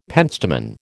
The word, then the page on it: pentstemon: American English